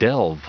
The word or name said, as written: delve